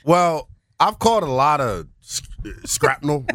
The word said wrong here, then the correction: shrapnel: recently. – Scrapnel? – Shrapnel, whatever the word is. It's early. How do you say it? Is it shrapnel? – Shrapnel.